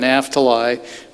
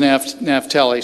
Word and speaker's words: Naphtali: That's how Scott Ashley pronounced it. I always called it Cana, but he pronounces it Cana, as well as Naphtali. I never pronounced it that way. I always called it Naphtali, but he says Naphtali.